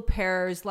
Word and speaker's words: Huppert: I think part of the problem with your Googling is that Isabelle Huppert's last name like has a silent H and a silent T.